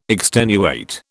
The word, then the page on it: extenuate: British English